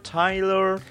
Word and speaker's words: Tyler: ¿Es Taylor? ¿Tyler? – ¿Cómo se pronunciará? Creo. Tyler. Tyler. – Porque, yo dije Taylor porque como no es Swift y se escribe sin la A, dije no sé.